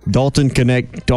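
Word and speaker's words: Dalton Knecht: Connect the dots. Dalton Connect, almost 20 a game.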